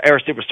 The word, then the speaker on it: Arecibo: Oh, you're talking about from Arecibo. – Yeah, Arecibo, sorry.